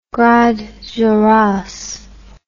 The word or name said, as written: Grandes Jorasses